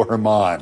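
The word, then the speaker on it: Germán: Although it's spelled Germain, he pronounces it Domingo Herman.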